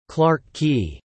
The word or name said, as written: Clarke Quay